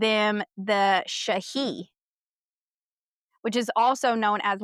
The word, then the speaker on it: sidhe: It's spelled S-I-D-H-E, but it's pronounced Shahi.